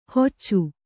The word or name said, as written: ಹೊಚ್ಚು